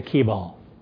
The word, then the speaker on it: Kibal: I'm pretty sure they pronounce it Kibal. It's K-I-B-A-L, or Kibal.